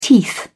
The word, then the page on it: teeth: British English